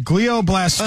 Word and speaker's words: glioblastoma: Stage 4, glio. Is that how you say it? – Glioblastoma.